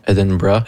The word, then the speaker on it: Edinburgh: Edinburgh.